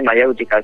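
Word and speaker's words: Maièutica: No, sí, Mayéutica, suena Mayéutica. – Igual que en castellano, sí.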